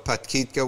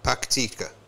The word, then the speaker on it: Paktika: Ariana News reported that Sharafuddin Muslim, the Deputy State Minister for Disaster Management, said Barmal, the Barmal district of Patkitka was most affected. Paktika.